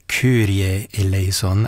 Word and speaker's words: Kyrie eleison: Johann Elia Gardner with his Monteverdi Choir and English Baroque soloists adopting the German pronunciation of that Greek section of the Latin Mass, the Kyrie Eleison, singing it more like Kyrie Eleison, as it would have been in the Germany of Bach's day when he wrote that highly impractical Mass in B minor, his greatest work.